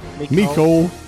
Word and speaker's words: Mecole: Mecole.